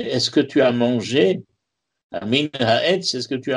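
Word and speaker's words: ha min (המן): Alors Aman, ça s'écrit « He min » He mem nun, et he mem nun, vous pouvez le lire ha min.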